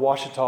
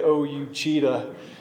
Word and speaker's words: Ouachita: It's spelled Ouachita, O-U-A-C-H-I-T-A. If you looked at it, you would never say Ouachita. You would look at it and say O-U-Cheetah.